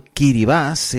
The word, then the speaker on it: Kiribati: República de Kiritiba. Kiribati. No Kiritiba. República de Kiribati. Los primeros en cruzar a Internet 2015 con su dominio .kai. Kiribati, pronunciado Kiribas en Gibertés,